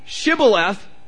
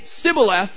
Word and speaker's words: shibboleth: And if they say shibboleth, let them pass. If they say sibboleth, kill them. And 42,000 people were executed because they couldn't pronounce shibboleth.